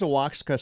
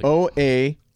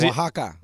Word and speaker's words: Oaxaca: Where's Oaxaca City? – I don't think you're pronouncing that correctly. – Oaxaca. – How does Oaxaca.